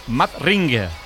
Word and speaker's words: Madring: Pero, ¿cómo se dice Madrid? – Madrid. Madrid. Teóricamente Madrid. Madrid. Madring. – Madring.